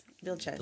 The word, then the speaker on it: Vilchez: Vilchez.